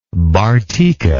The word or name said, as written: Bartica